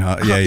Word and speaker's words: Haaland: So I saw, and I don't know if it was a meme, but again, I saw something on the socials where Haaland, is that how you say it? – Erling Haaland. Yeah.